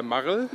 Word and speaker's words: Marl: Marl? – Yeah.